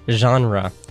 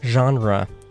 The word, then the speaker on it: genre: Okay, the next word is genre, and uh, it sounds French, right? 对 guess what? That's because it is French. At least it's English, but it comes from French, and uh, so it's pronounced genre.